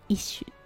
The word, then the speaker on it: ish: これ ISH と書いて「一種」と読みますこれすごくよく使うしすごく便利な単語です。